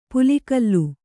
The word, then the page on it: ಪಲ್ಗಲೆ: palgale